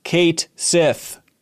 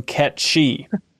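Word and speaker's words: Cait Sith: is pronounced Kate Sith and not the Scottish Gaelic pronunciation Ketchi.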